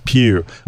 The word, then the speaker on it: PHEV: And it says PHEV, which I'd like to pronounce pew. It's yeah. Not, but pew. It's just, it's like the EU. EU.